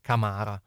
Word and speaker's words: Camara: Poi un altro ascolta Orlando che dice: il Parma come centrocampisti, oltre a Soma, Camara o Camara, che dir si voglia, anche se la pronuncia corretta dovrebbe essere Camara, Buai Chiala e Aino, anche se gli ultimi due sono ancora da scoprire.